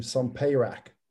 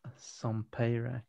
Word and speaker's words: Sompayrac: Sompayrac. – Whoa, can you repeat that, please? How the Immune System Works by Lauren M. So, and I'm going to enunciate this surname correctly Sompayrac. – Sompayrac, okay.